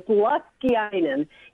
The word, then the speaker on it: Laskiainen: So Saturday and Sunday is the actual how do you say it again, Luskeiden? – You say it perfectly. Don't ask Peter Wood to say it. He murders it horribly. But it is Luskeiden, yes.